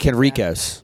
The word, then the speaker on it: Henriquez: Why do I call him Hendrix? I don't know. I always do that. Sorry. – You see the Q, maybe? I don't know. – No, I butcher his name every time.